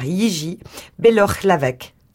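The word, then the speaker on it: Jiří Bělohlávek: Jiří Belohlávek.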